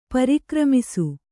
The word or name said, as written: ಪರಿಕ್ರಮಿಸು